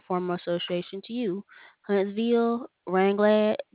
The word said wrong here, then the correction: Ragland: Huntsville, I believe it's pronounced Ragland, Jasper, Hoover, and then none of the above.